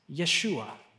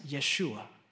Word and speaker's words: Yeshua: That word, salvation, in the Hebrew, is a word, and it is pronounced Yeshua. Yeshua. This was written 700 years before Jesus came. Do you know what Jesus' name is in Hebrew? It's not pronounced Jesus. It's pronounced Yeshua.